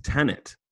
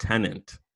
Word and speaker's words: Tenet: It's Tenet, not Tenant. I've been saying it wrong. Tenet. Not Tenant. It's Tenet.